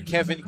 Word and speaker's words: Kevyn: So Burns is retired, and now it's the left fielder Kevin Castillo digging in on the left side. Now, Kevin, pronounced how you would, but spelled K-E-V-Y-N.